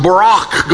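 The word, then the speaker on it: Barak (ברך): And God even coined a word for that, the B sound in Hebrew with the short A and the R sound in Hebrew, right to left, and the shorter A and the long, harsh K sound, Barak.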